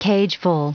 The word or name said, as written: cageful